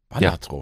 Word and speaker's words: Balatro: Ich habe jetzt gehört, das heißt Balatro. – Ah, okay. – Balatro. Ja, eigentlich wird es Balatro ausgesprochen.